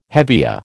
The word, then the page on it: hevea: American English